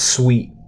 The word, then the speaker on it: Sweat: I'm going to go with Sweat for now, but I think this pick will change a few times. – I know it's spelled Sweat, but it's pronounced Sweet.